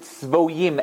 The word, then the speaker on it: Tzvoyim: It's spelled Sadi Beis Yud Yud Mem. But we pronounce it Tzvoyim as if it's Vav Yud Mem.